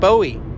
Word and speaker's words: Bowie: And he, I believe, took the name from a family name, Bowie, but we pronounce it Bowie.